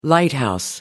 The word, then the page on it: lighthouse: American English